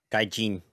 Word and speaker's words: Gaijin: Gaijin.